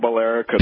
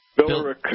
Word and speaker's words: Billerica: You know now. It's not Billerica. – Billerica? – Billerica.